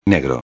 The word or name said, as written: negro